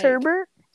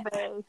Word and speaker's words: sherbet: And there's no R in that? – Sherbet. – Sherbet. That's how it's spelled, bruh. Sherbet. Like, you don't say that.